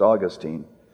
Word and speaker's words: Augustine: He was convicted of his sins, and he believed the gospel, and he turned out to be Augustine, sometimes pronounced Augustine, the great theologian and philosopher.